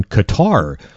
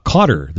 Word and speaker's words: Qatar: Next up on the sporting calendar today, Canada's second game at the World Cup, taking on Qatar, or Cotter this afternoon, depending upon how you want to pronounce it.